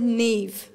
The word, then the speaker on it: Niamh: So it's said Niamh, like it's got a V in it, but it's got no V in it.